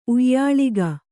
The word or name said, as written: ಉಯ್ಯಾಳಿಗ